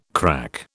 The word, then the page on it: crack: British English